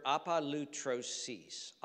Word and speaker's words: apolutrosis: This is the word apolutrosis.